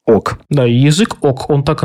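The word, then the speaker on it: oc: слово «да» произносилось как «ок».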